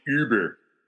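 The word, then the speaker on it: Über: So at some point, we're all going to realize that really all along we should have been pronouncing it Uber. – Uber. – Yeah, that's proper.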